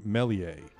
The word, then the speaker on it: Meslier: I mean, it's spelled, M-E-S-L-I-E-R. So I had heard people say Meslier, but now I'm exclusively hearing Mellier.